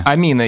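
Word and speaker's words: Aminé: Произносит его Амина, несмотря на апостроф э, в конце, что как бы предусматривает, что и на конце должно читаться. А, и вообще туда типа должно ударение падать. Но нифига. Амина.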